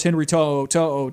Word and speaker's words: Henry To'o To'o: But of course, Henry To'o To'o to Alabama, that kind of flips the scale a little bit on defense. – Nice pronunciation, by the way. I never knew how to pronounce his last name, so it's good to know that.